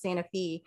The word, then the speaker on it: Santa Fe: So it's spelled like Santa Fe, New Mexico, but pronounced Santa Fe.